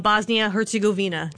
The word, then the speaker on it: Bosnia-Herzegovina: And they're taking on Bosnia-Herzegovina.